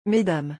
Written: mesdames

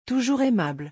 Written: toujours aimable

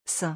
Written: saint

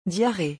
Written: diarrhee

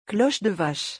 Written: cloche de vache

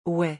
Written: ouais

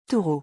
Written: taureau

